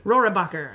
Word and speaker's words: Rohrabacher: how do you say it again? – Rohrabacher. – Rohrabacher. Thank you.